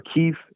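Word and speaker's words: Kyiv: It's near the border with Poland, and it's about 500 kilometers west of Kiev, or Kiev is how the Ukrainians pronounce it.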